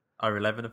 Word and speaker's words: Levin: Ira Levine? – Ira Levin, I think it's pronounced. Ira Levin.